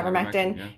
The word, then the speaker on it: ivermectin: Yeah, ivermectin.